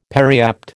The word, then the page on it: periapt: American English